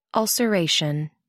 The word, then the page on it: ulceration: American English